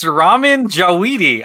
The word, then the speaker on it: Ramin Djawadi: I don't know how to say his name. It's Ramin Djawadi. – I'm going to give it to you because it's Ramin Djawadi.